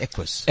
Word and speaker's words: Equus: Equus.